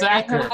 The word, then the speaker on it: Sachar: Yeah, Louis, I think it's Louis Sachar is how you say his name. – I thought it was yeah. – Sacker. – Is it Sacker? – I thought it was Sachar. – It's Sacker.